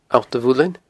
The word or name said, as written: Allt a Mhuilinn